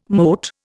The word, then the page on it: mote: Indian